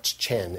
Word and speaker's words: chen: Yeah, it's spelled, I can spell it C-H-E-N, but that's not how it's pronounced. It's not chen, it's chen. You gotta have that little bit of a Hebrew accent on it.